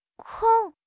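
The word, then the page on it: kong1: tone 1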